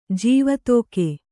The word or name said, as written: ಜೀವತೋಕೆ